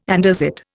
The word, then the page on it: andesite: Indian